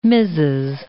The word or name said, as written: Mses.